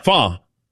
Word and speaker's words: pho: Fa? – Fa. – Oh. – Yeah, P-H-O.